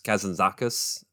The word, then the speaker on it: Kazantzakis: Now, I have not read the book uh, that this is based on, The Last Temptation by uh, Nikos Kazantzakis. Uh, that's how Scorsese pronounces his last name. So it's like, oh, I guess that's how you say his name because I wouldn't have known how to pronounce it otherwise.